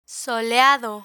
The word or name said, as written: soleado